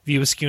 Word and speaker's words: View Askew: I don't think I'm saying that right. View Askew universe.